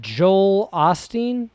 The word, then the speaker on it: Joel Osteen: But I think we're going to talk about, just real quick, there's a guy, Joel Osteen.